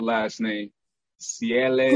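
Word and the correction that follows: Seales: No, it is. No, it's Seals.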